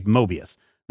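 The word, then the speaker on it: Moebius: So number one, I went and found some documentaries about Mobius, and everybody who mentioned his name in there, from Stan Lee to Mike Mignola and all these others, said Mobius.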